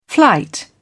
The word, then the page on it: flight: British English